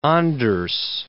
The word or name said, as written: Anders